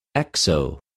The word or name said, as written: ἑξω